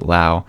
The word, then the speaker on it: Lau: Brandon Lau spells his last name exactly the same, but pronounces it Lau. Nate pronounces it Lowe.